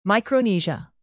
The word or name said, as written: micronesia